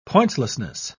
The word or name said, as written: pointlessness